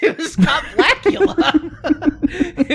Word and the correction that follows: Scott Bakula: It was Scott Bakula. – What was his character's name in Quantum Leap? – I don't even know. He's just always Scott Bakula to me, because his name rhymes with Dracula, so it's kind of cool.